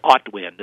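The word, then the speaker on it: Autwin: And so they give us just this tremendous encore as we now start to enter Otwin. This is the beginning of Otwin. Otwin is the period of time. The word is spelled capital A-U-T, capital W-I-N, and it's the period of time in between the leaf drop and the snow cover.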